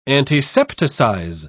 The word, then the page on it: antisepticize: British English